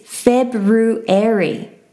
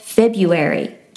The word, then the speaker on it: February: But the correct way of pronouncing the month February is February instead of February.